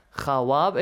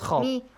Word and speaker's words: خواب: In Persian as well, we have khawab. It's written, but it's pronounced khab. – Khawab is silent.